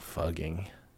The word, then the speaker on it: Fugging: Fugging is supposed to better reflect the pronunciation of the town's name, but I think that's a cop-out.